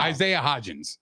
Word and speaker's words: Hodgins: Isaiah Hodgson and Richie James are not getting past Bradbury and Slay. – No, say his name with a little bit of respect. It's no. Isaiah Hodgins.